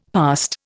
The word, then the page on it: past: Indian